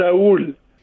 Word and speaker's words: Raul: Our, our in Spanish is Raul. So it's it's it is raw, like like once like R A W L, like yeah.